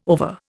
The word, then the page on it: ova: Indian